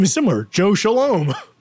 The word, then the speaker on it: Scialom: It's spelled like Skialom. It's like the Italian way of saying Shalom.